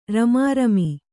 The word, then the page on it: ರುಮುರುಮು: rumu rumu